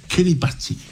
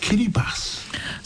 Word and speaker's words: Kiribati: Now, for many years, I kept saying Kiribati, not realizing that it's actually pronounced Kiribati. – So the T-I is a S.